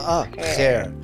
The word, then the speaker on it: Khair: Khair. – See? Go ahead. – Yeah. Khair. You have to roll your tongue a bit. – Khair. Khair, like that, yeah? – Yeah.